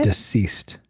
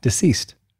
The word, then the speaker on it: deceased: I pronounce a Z. You don't pronounce disease. – A Z? – That sounds like diseased. – Exactly. – No, that's like disease. That means you have like a disease. – Oh, so how do you say it? – Deceased. – Oh, forget it. – pronounce the C.